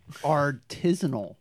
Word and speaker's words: artisanal: Artisanal. – Artisanal. – Did I pronounce that right? – No, it's art-isanal.